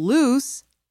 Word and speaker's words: loose: In speaking, both words use the vowel sound oo. But lose ends in the z sound, while loose ends in the s sound.